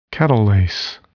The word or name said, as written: catalase